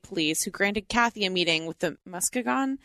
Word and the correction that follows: Muskegon: I don't think that's how it's pronounced. – Let's look it up. – Muskegon. Muskegon. It sounds like a dinosaur.